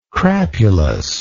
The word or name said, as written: crapulous